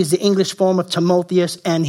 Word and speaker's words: Timotheus: Timotheus is how you pronounce it, but he's called a true uh, son of the faith.